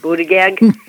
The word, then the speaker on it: Buttigieg: I mean, and it's affected everybody in the world, and yet our head of our transportation secretary, Pete Buttigieg, or however you pronounce it, Buttigieg.